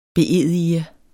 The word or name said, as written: beedige